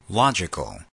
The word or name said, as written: logical